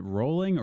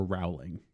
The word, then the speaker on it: Rowling: Now, my last question is, do you pronounce it Rowling or Rowling? – Ooh. – Because I've heard it both ways. – I say Rowling. – Rowling? – J.K.